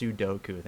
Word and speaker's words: Sudoku: Sudoku.